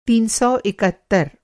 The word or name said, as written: तीन सौ इकहत्तर